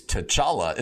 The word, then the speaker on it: T'Challa: That is T'Challa. Am I saying that right? In Black Panther? – Yep.